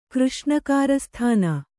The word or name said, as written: ಕೃಷ್ಣಕಾರಸ್ಥಾನ